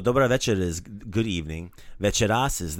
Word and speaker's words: večeras: So accent the first syllable. Like usual, like Hrvatska, not Hrvatska or Hrvatska, right? It's večeras. Večeras. – Hrvatska coming soon. – True. – Oh jeez. – Večeras.